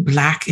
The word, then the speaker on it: Blaque: Black is spelled B-L-A-Q-U-E.